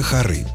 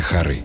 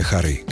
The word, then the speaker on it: хоры: Если мы говорим о певческих коллективах, то это хоры. Хоры мальчиков, камерные, церковные, знаменитые русские народные хоры. Если речь идет о музыкальных инструментах или произведениях, ударение также падает на последний слог. Скрипичные хоры, оперные хоры, как ключевые коллективные сцены. Птичьи тоже хоры. А вот в архитектуре хоры.